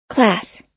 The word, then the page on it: class: American English